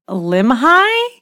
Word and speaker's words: Limhi: And he said unto them, behold, I am Limhi? Is that how you say it? – Mm-hmm.